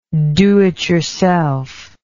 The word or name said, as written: do it yourself